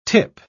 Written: tip

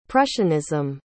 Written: prussianism

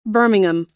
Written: birmingham

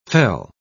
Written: fell

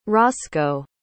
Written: rosco

